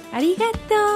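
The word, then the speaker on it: arigatō: Uy, imagínate mis comentarios de Ichinisa, ña, arigato. ¡Arigato! No, así no es, es arigato.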